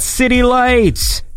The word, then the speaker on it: Zitilites: I think we played a track of his the other day, although it's spelled Z-I-T-I-L-I-T-E-S, which makes it very confusing, but I know now that it is indeed City Light.